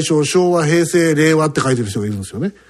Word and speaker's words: 令和: だから言われる前に、令和っても発音しました。一番とか思い。 – あらららら。 – 最初ね、令和なのか、両和って読ませんのかと、ちょっと、どっちなんだろうと思ったんですけど、令和でしたね。